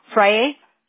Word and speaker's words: Frayre: Friday, F-R-A-Y-R-E. – F-R-A-Y-R-E? – Friday, F-R-A-Y-R-E. – Y-R-E, okay. Friday, is that how you pronounce it? – Friday.